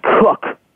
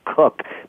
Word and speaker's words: Koch: It's also pronounced Cook. That's my explanation. My mistake. Let me correct myself. Max Cook, but it's spelled Koch.